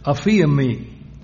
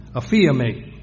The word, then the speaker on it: aphiemi: The word forgiveness comes from the Greek word aphiemi, aphiemi. A-P-H-I-E-M-I, or aphiemi, sometimes is pronounced that way.